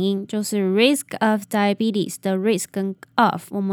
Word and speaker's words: diabetes: 那后面的 t 呢，我们会弱化它，然后第二个 e 其实也是发 e，然后最后的 s 呢要发 z 的音，所以这个字念作 diabetes diabetes。